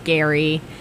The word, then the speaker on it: Gerry: I think the lead guy, his name is Gary, spelled G-E-R-R-Y, but pronounced Gary.